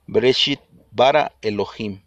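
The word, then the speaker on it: Bereshit bara Elohim (בראשית ברא אלהים): este es el original hebreo, como se pronuncia, esta es la fonética, Bereshit bara Elohim.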